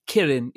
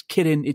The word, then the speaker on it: Kirin: Kirin Ichiban. And I mispronounced Kirin. It's Kirin Ichiban.